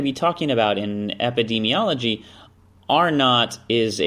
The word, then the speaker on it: R-naught: known as R-naught. You know, kind of like you're in an argument with your sibling. R2, R-naught. That's how it's pronounced.